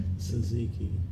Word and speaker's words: Tzatziki: Tzatziki. How do you say that? – Tzatziki. – I've never heard it said like that.